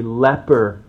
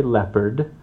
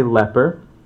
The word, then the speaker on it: leper: Let's make sure I'm clear in my pronunciation, not a leopard. That's what my children would want to hear every time I said it. But this is a leper.